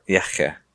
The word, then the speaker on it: Yehya: Yehya.